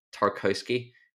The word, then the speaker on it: Tarkowski: But I think Tarkowski, when he played, I've had an absolute stinker pronouncing his name twice. Let's go again. Tarkowski, when he plays, has been a leader on the pitch for them and been really, really good.